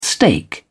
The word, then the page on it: stake: British English